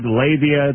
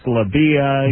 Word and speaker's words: labia: She goes, you know, you didn't pronounce, you said labia, it's labia, you know.